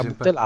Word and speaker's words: Boutelat: La Sophie Boutelat, giusto, si pronuncia così, esatto? – Perché francese, sarà Boutelat, allora guarda.